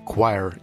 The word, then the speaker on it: choir: Английский такой волшебный язык, в котором обычное слово хор произносится как choir, не имея при этом половины тех букв, которые произносятся.